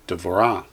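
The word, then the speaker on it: Devorah (דבורה): And number 4, when the letter Hey comes to the end of a word without a vowel, it's silent. We just talked about that with the example of Deborah, or in Hebrew, Devorah.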